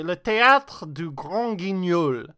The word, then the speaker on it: le théâtre du Grand Guignol: Okay, le théâtre du Grand Guignol.